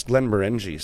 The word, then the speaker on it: Glenmorangie: I don't know how that's pronounced. I think it's Glen Morangi. Somebody told me once because I said Morangi.